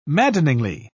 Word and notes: maddeningly: British English

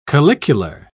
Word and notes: calicular: British English

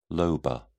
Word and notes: lobar: British English

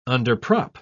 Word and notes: underprop: British English

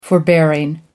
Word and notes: forbearing: American English

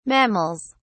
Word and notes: mammals: British English

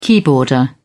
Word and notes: keyboarder: British English

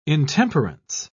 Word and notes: intemperance: British English

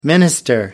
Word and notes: minister: American English

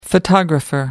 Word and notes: photographer: American English

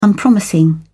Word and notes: unpromising: British English

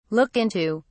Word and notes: look into: British English

